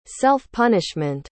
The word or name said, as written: self-punishment